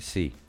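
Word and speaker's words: See: Λοιπόν, η Atlantic, Γιάννη, προφέρεται Sea. Okay.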